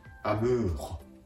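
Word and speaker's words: amour: R の発音は、うがいをするような、という発音に、ハヒフヘコの、この場合では、ふを添えるような形で、アムーホ、アムーホと言ってあげると、うまく発音ができます。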